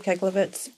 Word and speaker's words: Keglovitz: K-E-L-L, your first name. Yeah. K-E-L-L-I, and then your last name. – Keglovitz. – Just like love. – Keg, love it.